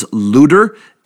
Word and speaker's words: Luder: He is born to Margaret and Hans Luder, L-U-D-E-R. That's the way it would have been locally pronounced.